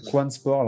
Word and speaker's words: Quan Sport: Et tu t'es pas arrêté là, parce que tu as aussi créé un autre projet plus récemment euh, qui s'appelle Quan Sport. Alors, je sais pas si ça se dit comme ça ou. – c'est ça, c'est bien, tu le prononces bien. C'est parfait.